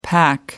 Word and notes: pac: American English

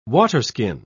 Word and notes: waterskin: British English

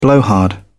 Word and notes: blowhard: British English

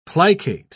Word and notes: plicate: British English

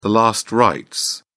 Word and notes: last rites: British English

